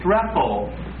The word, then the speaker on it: strepho: The first word is strepho. Strepho, that's spelled S-T-R-E-P-H, long O.